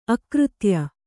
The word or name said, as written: ಅಕೃತ್ಯ